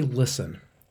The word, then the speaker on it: LSTN: The company Listen, which is spelled L-S-T-N, was founded in 2013 by Bridget Hilton and Joe Huff.